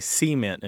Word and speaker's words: cement: The kind of people who say cement and Detroit. – Uh-huh. – Right? It's right. – Just the emphasis is on a different syllable than you might typically encounter in mainstream English.